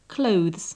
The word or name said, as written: clothes